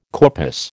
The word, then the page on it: corpus: American English